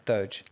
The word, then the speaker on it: Doge: That's surely not how it's pronounced. – I think he's going for Doge. – It's Doge. – Is it Doge? – Doge coin.